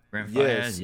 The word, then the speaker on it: Brent Faiyaz: Brent Faiyaz, is that how you say his name? – Brent Faiyaz, yeah.